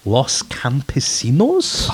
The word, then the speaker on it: Los Campesinos: Los Campesinos? – Fucking nailed it, man. Well done. That's really impressive. I called them Los Campesinos for like...